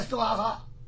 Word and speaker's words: 娑婆诃: 娑婆诃在古代那个汉字就念斯瓦哈，就不念娑婆诃。现在念成现代音了，就娑婆诃了。